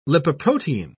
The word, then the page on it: lipoprotein: British English